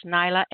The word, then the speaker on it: Nyla: If you're not on my contacts list, it's Nyla, N-Y-L-A, last name Alisia, A-L-I-S-I-A.